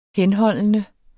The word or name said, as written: henholdende